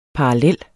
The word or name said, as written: parallel